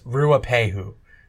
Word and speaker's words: Ruapehu: It's Ruapehu.